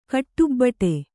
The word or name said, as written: ಗಡಬಡ